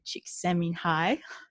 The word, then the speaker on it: Csikszentmihalyi: which you co-wrote with Mihai Csikszentmihalyi. I hope I said that right. – Well done. Awesome. Most people don't get it right first time.